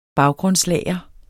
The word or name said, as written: baggrundslager